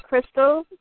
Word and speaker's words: Crystal: Crystal, C-R-Y-S-T-A-L, Crystal.